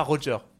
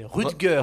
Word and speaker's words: Rutger: Alors, c'est pas Roger. – Roger Hauer, non, je sais pas. C'est Rutger. – Rutger Hauer, mais je sais pas. – Rutger Hauer. – Je sais pas comment tu prononces. – Ça passe, ça passe. – Quoi? Il a dit Roger et c'est Rutger. – Je sais pas comment ça se prononce. Pour moi, je dis, mais j'avais Roger. Rutger. – Rutger Hauer. – Rutger Hauer, ok. – Ouais, ça se dit Rutger.